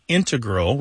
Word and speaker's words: integral: You mainly hear integral with the stress on the first syllable among mathematicians and specialists in scientific fields that use a lot of math.